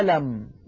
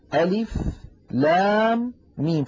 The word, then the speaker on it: الم: فهذه الحروف المقطعة عندما نقرأها في القرآن لا نقرأها هي، وإلا لقلنا ألم بدل ألف لام ميم، لكننا نقول ألف لام ميم، فننطق أسماء هذه الحروف.